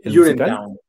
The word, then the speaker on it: Urinetown: la parte complicada con Orineton, así se pronuncia. – Urinetown. – Urinetown, ok.